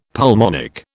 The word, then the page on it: pulmonic: British English